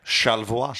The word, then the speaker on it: Charlevoix: C-H-A-R-L-E-V-O-I-X. – Yeah, Charlevoix.